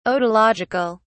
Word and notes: otological: British English

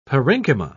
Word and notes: parenchyma: British English

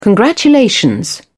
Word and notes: congratulation: British English

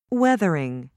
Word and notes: weathering: British English

